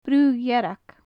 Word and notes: bru dhearg: gaelic

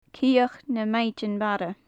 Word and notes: cioch na maighdinn mara: gaelic

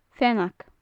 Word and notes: feannag: gaelic

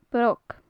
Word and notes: broc: gaelic

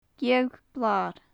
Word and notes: geadh blar: gaelic

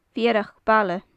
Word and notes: feurach baile: gaelic

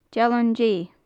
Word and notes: dealan de: gaelic

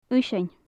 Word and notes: uinnseann: gaelic

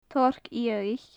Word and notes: torc fhiadhaich: gaelic

